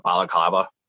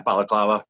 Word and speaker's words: balaclava: I had a black like hood over my face, kind of like a balaclava almost. Yeah, is that how you call it? – You said it right. – Yeah, balaclava.